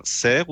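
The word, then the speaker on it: Sers: Et on va attaquer ça avec les oubliés de Gauvain Serre ou Serre. Si tu insistes à chaque fois, tu me corriges. OK. Le S est prononcé, donc apparemment.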